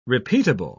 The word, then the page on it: repeatable: British English